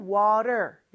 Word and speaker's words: water: 특히 미국에서는 저 water의 T 발음이 리을처럼 짧게 발음이 되죠? 그래서 water라고 하지 않고 water 이렇게 발음을 하는 거고요.